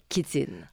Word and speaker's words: chitine: La chitine, qui s'écrit chitine. Oui. C-H-I-T-I-N-E et qui se prononce chitine, qui K-I-T-I-N-E.